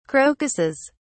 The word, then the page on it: crocuses: British English